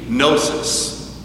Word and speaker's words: gnosis: In Greek, the word for knowledge has a silent G, gnosis.